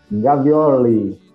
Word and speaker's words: Gavioli: O nome dele é Isaac Storoli Gavioli mas tem a pronúncia correta. Isaac Gavioli, que é italiano.